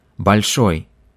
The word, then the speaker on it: Большой: Please note that Bolshoi is pronounced stressing the end of the word in Russian. So let's practice this word a few times. Большой, большой, большой, большой театр, большой театр, большой театр.